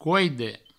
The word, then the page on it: coide: couide